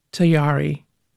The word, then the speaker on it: Tayari: Let's get that name right. Tayari. – Mm-hmm. Rhymes with Ferrari and Oops, Atari.